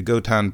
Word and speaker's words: Gotan: G-O-T-A-N, an anagram for tango, the Gotan Project. I pronounce it Gotan because it reminds me of Gohan. And when I first saw it, I read it as Gohan.